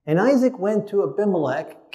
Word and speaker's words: Abimelech: Both had famines, both went to Abimelech, as it's pronounced in Hebrew.